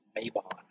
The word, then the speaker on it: Mabon: On one of the names, in the southern United States, I've never heard it pronounced Mabon, which I think is the more correct way to say it. We put a drawl on it. It's Mabon.